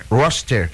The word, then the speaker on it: Worcester: Como é que é a pronúncia? – Worcester.